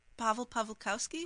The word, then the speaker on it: Paweł Pawlikowski: Paweł Pawlikowski.